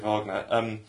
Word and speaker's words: Wagner: The fact that Neil Wagner, I'm told that it's pronounced Wagner by the Sky commentators. – You want to say Wagner, but you do want to say Wagner.